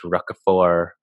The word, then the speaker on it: Roquefort: I kept saying Roquefort, but it's Roquefort. – There's no hard T.